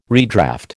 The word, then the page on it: redraft: American English